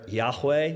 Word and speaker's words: Yahweh: They've come to the conclusion that it's better, Yahweh would be a better pronunciation.